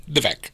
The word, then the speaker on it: Vivek: Vivek.